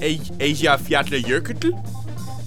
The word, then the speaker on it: Eyjafjallajökull: How close did I get the name pronunciation? – Eyjafjallajökull? Pretty close. – That was pretty close.